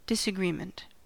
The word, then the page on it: disagreement: American English